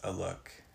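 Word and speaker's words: Alok: I think their name is pronounced Aluk. It's A-L-O-K.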